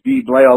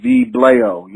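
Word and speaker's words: Vee Blaio: V-E-E underscore B-L-A-I-O. It's V-Blaio. A lot of people have a hard time pronouncing my name, but it's like Vallejo, but it's like V-Blaio. You know what I'm saying? It's V-Blaio. So, you know, just rhyme V-Blaio with Vallejo.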